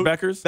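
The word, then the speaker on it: Bueckers: Yeah, the Paige girl, Beckers.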